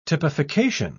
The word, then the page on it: typification: British English